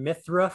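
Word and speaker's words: Mythra: Yeah, and then we had Pyra and Mithra from Xenoblade series. I'm probably not pronouncing those names correctly. – No, you got those games perfect. Yeah, you got those names perfectly.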